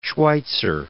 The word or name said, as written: Schweitzer